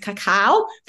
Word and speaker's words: cacao: We've actually also been using, we call it cocoa, but people pronounce it cacao from Samoa.